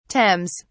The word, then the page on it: Thames: British English